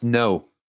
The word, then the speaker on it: Ngo: But this Andy Ngo guy, NGO, I don't know how to pronounce that. – No. It's no.